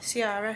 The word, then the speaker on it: Ciara: How did you pronounce her, the artist? – Ciara. – Is that the right way? – Yeah.